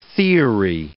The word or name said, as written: theory